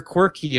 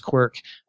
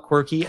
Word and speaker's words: Qwerke: And and so I, I, the pronunciation of it, I always have to kind of put in parentheses, pronounce quirk, because um, uh, people will call it quirky, and that's okay. But um, I just kind of like when we said quirk.